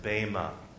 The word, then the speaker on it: Bema: B-E-M-A. Some people choose to pronounce it Bema. I choose to pronounce it Bema. There's liberty in the pronunciation, dear friends.